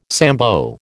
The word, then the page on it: Sambo: American English